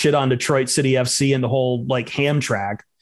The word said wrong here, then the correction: Hamtramck: Am I saying that right? I can never say Hamtrak's right. A name, right? – Hamtramck. – Hamtramck, excuse me.